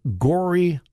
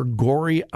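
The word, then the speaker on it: Gorée: But my understanding of slavery was changed dramatically when I visited Gori Island, just off the coast of Africa. Gori Island, I believe it's spelled G-O-R-E-E, if you want a fascinating study. Do a little internet search for Gori Island. Again, I believe it's G-O-R-E-E.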